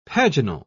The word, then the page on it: paginal: British English